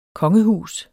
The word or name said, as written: kongehus